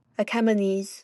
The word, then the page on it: Achaemenes: /əˈkɛməniːz/